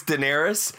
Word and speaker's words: Daenerys: By the way, everyone, every time I'm on a Zoom call with Jake, we're doing junkets, everyone pronounces her name wrong. Like, yeah. I don't know if Jake, like, Jake's gotten to a point where he doesn't even correct it anymore. Because I know it's Daenerys.